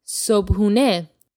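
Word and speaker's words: sobhāné (صبحانه): In Persian, breakfast is called sobhāné. – Sobhāné. – You'll also hear this pronounced sobhāné from time to time.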